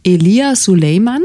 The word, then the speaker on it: Elia Suleiman: Der dritte Film, über den wir sprechen wollen, heißt Vom Gießen des Zitronenbaums, ist von Elia Suleiman, das ist richtig ausgesprochen.